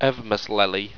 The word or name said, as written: evmeslelley